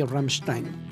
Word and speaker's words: Rammstein: Es muy curioso cuando la gente te conoce porque, no sé si lo sepan, pero Naum es sumamente exageradamente fan de Rammstein. ¿Sí lo pronuncia bien? – Sí, Rammstein.